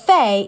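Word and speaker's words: Faye: Sure, it's Sharon Fay. How do you spell it? Fay, it's F-A-Y-E, like this.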